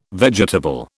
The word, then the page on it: vegetable: British English